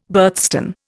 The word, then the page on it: birthstone: Indian